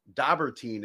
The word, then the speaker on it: Dobberteen: Buddy Lee Dobberteen.